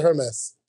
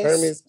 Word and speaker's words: Hermes: Is it Hermes or Hermes? Hermes. Hermes. Hermes. Oh, Hermes. Say it right.